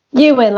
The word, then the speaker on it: Ewan: Ewan, like Ewan McGregor.